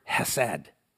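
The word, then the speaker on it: hesed (חסד): Well, this idea of loving kindness in Hebrew is expressed with the word hesed, H-E-S-E-D, hesed.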